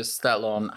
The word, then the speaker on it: Stallone: And they believe all Italians should look like Sylvester Stallone. How do you pronounce his name? – Yes, Sylvester. We say Stallone in Italian.